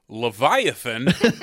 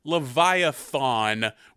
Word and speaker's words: Leviathan: I am excited to talk about Leviathan, that's how that word is pronounced, although I think it's the doctor in this movie fucking at one point says Leviathan, which is not right and completely threw me for a loop.